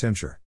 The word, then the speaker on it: Titmouseshire: Titmouseshire, pronounced Timshire. How rude of foreigners to botch the name of our beloved county, it's written right there on the signs.